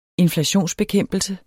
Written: inflationsbekæmpelse

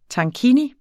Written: tankini

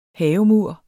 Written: havemur

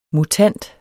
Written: mutant

